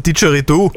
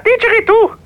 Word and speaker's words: Didgeridoo: Äh, so ähnlich wie Kikeriki. Wie spricht man das aus? – Ach so, du meinst der Didgeridoo. – Ja, genau, ein Didgeridoo.